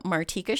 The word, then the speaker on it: Martika: So you can hire me by going to The Martika Show. And that is spelled my name, M-A-R-T-I-K-A.